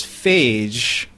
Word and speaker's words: Phage: Yeah, see, there's phage, which is the way we pronounce this episode, and then there's Fage, which is the yogurt.